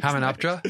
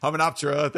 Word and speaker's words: Hamunaptra: And it kind of for me starts uh, certainly in that opening with the with the invasion at you know Hamna's. We're gonna butcher all these. Hamunaptra. – Hamunaptra.